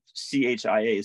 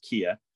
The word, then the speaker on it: Chia: And over where I was in, in Kia, which it, it's spelled C H I A. So you think chia like a chia pet, but it's actually pronounced Kia that area was actually pretty warm.